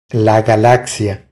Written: La galaxia

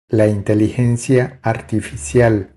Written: La inteligencia artificial

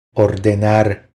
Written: Ordenar

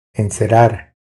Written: Encerar

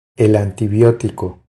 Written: El antibiotico

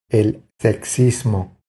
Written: El sexismo

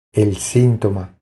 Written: El sintoma

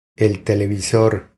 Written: El televisor

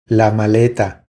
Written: La maleta

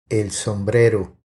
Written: El sombrero

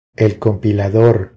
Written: El compilador

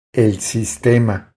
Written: El sistema

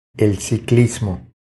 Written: El ciclismo

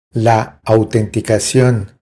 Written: La autenticacion

